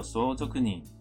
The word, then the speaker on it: 相続人: 好，那这边第一个知道的单字是这个汉字写作相续人，念作相続人。相続人相续是继承，那相续人就是继承人。这边就是提到的有关于继承人呢。相続人